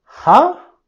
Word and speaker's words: huh: Huh? En español sería ¿eh? – Huh? Huh? – Aquí tenemos el sonido vocal con huh, huh, y la primer H lo pronunciamos, pero la H al final es silencioso.